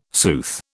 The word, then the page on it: sooth: British English